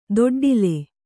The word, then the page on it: ದೊಡ್ಡಿಲೆ: doḍḍile